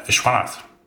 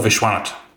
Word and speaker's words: Vishwanath: You have to take away the H at the end. – So, oh, Vishwanath. – But an accent on the first syllable.